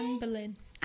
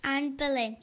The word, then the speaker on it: Anne Boleyn: Anne Boleyn. – Anne Boleyn, yeah.